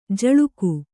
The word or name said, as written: ಝುಳುಕು